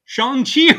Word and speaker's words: Shang-Chi: And speaking of like mispronouncing names, I found out because of this movie, I've been pronouncing Shang-Chi wrong for like 30 odd years. – It's Shang-Chi, right? – It's Shang-Chi, but it's spelled like Shang-Chi, which is how I, ignorant white dude, was pronouncing it.